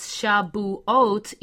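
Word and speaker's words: Shavuot (שבועות): And so the Feast of Weeks is pronounced as Shavuot. You see, Shavuot is the plural for Shabua. Shavuot means weeks, plural. And so those of you who might say, well, why do you pronounce it that way? Because that is the more accurate and ancient way.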